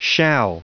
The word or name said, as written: shall